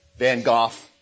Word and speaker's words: Van Gogh: Van Gogh, that's actually how you say it, apparently.